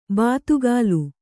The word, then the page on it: ಬಾತುಗಾಲು: Kannada